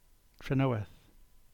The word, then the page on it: Trenoweth: English